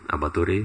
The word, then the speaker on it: avatari: Abhatori means avatari, descends. Right? This is Bengali pronunciation.